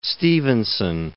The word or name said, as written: Stevenson